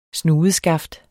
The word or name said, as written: SNUDESKAFTER